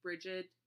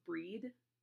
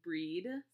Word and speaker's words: Brigid: I always say Brigid or Brigitte because that I'm Americanizing it, but it's pronounced Breed. I mean, I think Brigid sounds pretty cool if you ask me, but if you want to go with the Celtic pronunciation, it is Breed, along those lines.